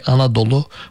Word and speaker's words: Anadolu: And Turks adopted that and they pronounced it with a particular Turkish way, Anadolu, which means full of mothers, actually.